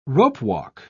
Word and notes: ropewalk: British English

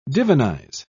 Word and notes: divinize: British English

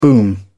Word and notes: boom: British English

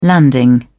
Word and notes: landing: British English

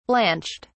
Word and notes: blanched: British English

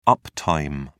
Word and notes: uptime: British English

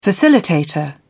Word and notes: facilitator: British English

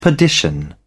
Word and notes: perdition: British English

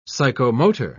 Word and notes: psychomotor: British English

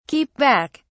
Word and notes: keep back: British English

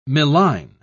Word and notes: milline: British English

